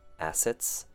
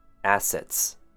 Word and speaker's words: assets: Next word, assets. Two syllables. A phonetic A sound. I stress this because a lot of people seem to have difficulty with the A and E sounds, so listen carefully. Assets. Assets.